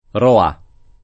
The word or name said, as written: Roà